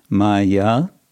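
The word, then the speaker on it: māyā: We have the word maya with long a in both cases, and then guna mayi, and that's a short a.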